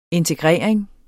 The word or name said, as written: integrering